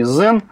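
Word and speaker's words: Xen: Ну, собственно, уж коль мы заговорили о Citrix и Zen, правильно произносить все-таки Zen. – Ну, Zen, да, да, согласен.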